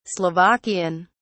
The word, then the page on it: slovakian: British English